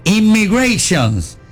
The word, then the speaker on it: Immigration: Inmigración and Custom Enforcement. Wow, lo dije bien. – Repítelo. – Espérate. No, porque dije en español la primera palabra. Es Immigration and Custom Enforcement.